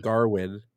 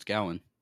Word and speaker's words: Gawain: The movie is based on the Arthurian legend of Sir Garwin. It's spelled Gawain. – I don't really care. – I thought it was Gawain. – No, it's Gawain. – They say Garwin though. – That's because their accent, but it's Gawain.